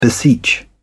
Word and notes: beseech: British English

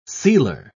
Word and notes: sealer: British English

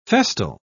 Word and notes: festal: British English

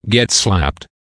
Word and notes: get slapped: American English

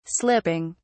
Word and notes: slipping: British English

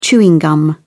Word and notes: chewing gum: British English

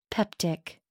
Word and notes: peptic: American English

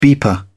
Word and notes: beeper: British English

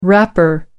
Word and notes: rapper: American English

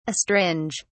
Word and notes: astringe: British English

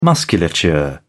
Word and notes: musculature: British English

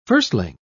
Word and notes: firstling: British English

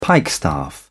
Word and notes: pikestaff: British English

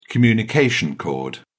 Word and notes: communication cord: British English

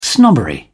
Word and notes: snobbery: British English

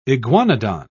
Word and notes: iguanodon: British English

